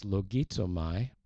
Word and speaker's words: logizomai: is logizomai, L-O-G-I-Z-O-M-A-I, logizomai.